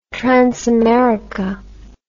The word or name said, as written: transamerica